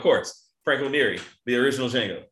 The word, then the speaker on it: Django: Yeah, Django. I know. Right. The two of them at the bar. The D is silent.